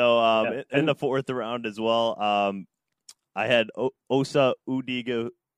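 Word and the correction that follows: Odighizuwa: Oh, man. Give me a moment. I need to pronounce his name correctly in respect to him. Odigizuwa. Osa Odigizuwa, 282,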